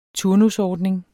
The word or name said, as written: turnusordning